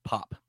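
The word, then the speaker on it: Pape: Diakite's first name is just pronounced Pop. And that Neil says it in there, I'm like, oh, okay, now I know. The E is silent.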